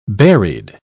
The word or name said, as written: berried